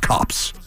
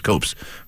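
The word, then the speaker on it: copse: It's pronounced cops. – Of chops. – Cops of trees. I thought it was copes.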